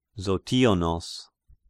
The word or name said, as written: Zotionos